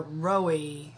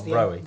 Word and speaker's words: Rohe: Yes, so do most folks. It's German. Rohe, I think is how you said it in German, but yeah, no, we immigrated to the States like a couple generations back, and that pronunciation took over.